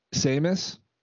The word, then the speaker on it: Samus: Was it Samus? I would say him too. But am I saying that right? – Samus.